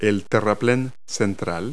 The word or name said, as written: median